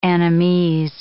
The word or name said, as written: Annamese